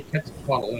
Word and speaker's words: Quetzalcoatl: So I looked it up on the interwebs, and apparently Quetzalcoatl, and I don't know if I'm pronouncing it right, is a deity in the Aztec culture. Got it right.